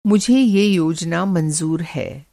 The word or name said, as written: मंज़ूर